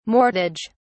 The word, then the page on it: mortage: British English